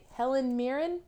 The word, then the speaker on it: Helen Mirren: And they are dismissed by Helen Mirren.